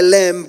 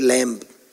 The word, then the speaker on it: lamb: as of a lamb without blemish and without spot." Apparently, in proper English, the B is silent in the word lamb. Nami, I was told by my wife for the first time.